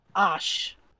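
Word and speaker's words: Ashe: Ash. – Ash. Like ashes.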